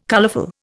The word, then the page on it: colourful: Indian